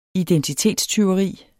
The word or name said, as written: identitetstyveri